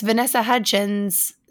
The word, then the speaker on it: Vanessa Hudgens: It's Vanessa Hudgens.